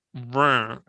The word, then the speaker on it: Reims: But this one really has to come from the back of your throat. I think it's Reims. – I'm not even sure the S gets any notice in there.